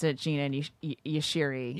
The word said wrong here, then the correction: Yashere: Yashiri? – And I can't stop. – Well, it's Jeanne Yachere.